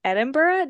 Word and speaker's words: Edinburgh: You're living in Scotland, which is near Edinburgh.